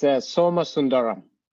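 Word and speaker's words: Somasundaram: It's Soma Sundaram. You just pronounce it the way it's written.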